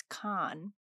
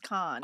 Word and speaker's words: Kahan: So and he's like, it's Khan. – Yeah. – You don't pronounce the H-A-N at the end. – Yeah. – It's just Khan.